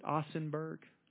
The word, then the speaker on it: Ossenburg: In 1945, his division had just been ordered to take the town of Ossenburg, Germany. Did I say that right? Ossenburg? O-S-S-E-N. Ossenburg, he lived there a number of years.